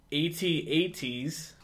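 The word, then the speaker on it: AT-AT: I mean, you see AT-ATs, which, side note, that is the correct pronunciation.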